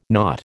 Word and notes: naught: American English

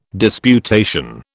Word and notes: disputation: British English

